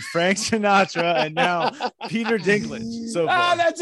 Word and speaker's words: Dinklage: Am I saying that wrong? Is there a proper way to say that? – We're good. – No, you said Dinklage exactly right.